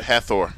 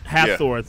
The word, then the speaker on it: Hafþór: Hafþór Bjornsson, I probably butchered his name. – You did, Hafþór. – Hafþór, yeah. Bjornsson, the Mountain, a.k.a.